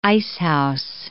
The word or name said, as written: icehouse